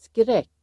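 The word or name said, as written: skräck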